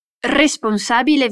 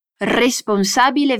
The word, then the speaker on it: responsabile: Responsabile vendite. Nota bene la pronuncia della R, è più vibrata. Responsabile vendite.